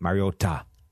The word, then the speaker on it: Mariota: It's just Mariota. – It's not. It's Mariota. – Mariota? – Yeah, yeah. It's a hard T. Got to give the hard T, otherwise the people get upset.